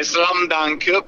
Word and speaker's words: Slums Dunk: La pronuncia è Slam Dunk perché Slam vuol dire baraccopoli.